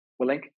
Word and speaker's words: Willink: Willink, who is a former Marine and Navy SEAL.